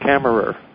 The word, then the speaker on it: Camerer: Her name is Christian Camerso. Did I say that correctly? – Actually, he's a Frenchman. It's Camerer.